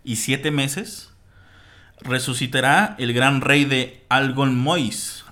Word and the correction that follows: Angolmois: Al, Al Angolmois, así es, güey, la pronunciación.